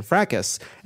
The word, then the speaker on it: Frakes: And I assumed for no reason that it was Jonathan Frakes. And Sam correctly said, no, no, no, you're wrong. It's obviously Frakes.